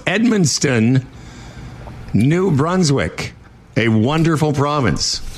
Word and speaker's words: Edmundston: But everybody I tell that I'm going to Edmundston thinks I'm pronouncing Edmonton wrong. – They want to correct. – They go, that's Edmonton.